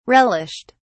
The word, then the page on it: relished: British English